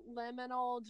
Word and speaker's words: liminal: So you're going to talk to us all about liminal dreaming today. Am I pronouncing it right? – You are pronouncing it right.